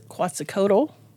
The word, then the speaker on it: Quetzalcoatl: In Mesoamerican cultures, Quetzalcoatl.